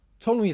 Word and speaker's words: Ptolemy: Ptolemy is spelled with a P, by the way, which is just super fun for dyslexic podcasters, let me tell you.